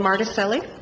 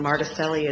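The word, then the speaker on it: Martuscelli: My name is Lynn Marticelli. I'll have to spell that for you, but L-Y-N-N-E, and Marticelli is M-A-R, T as in Tom, U-S-C, like the college, E-L-L-I.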